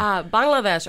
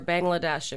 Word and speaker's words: Bangladesh: Bangladesh or Bangladesh if you're Canadian and you can't pronounce it properly, which is under China, next to India.